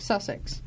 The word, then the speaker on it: Sussex: Sussex? – I don't know how you say it. – Sussex.